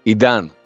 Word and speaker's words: Idan: Idan.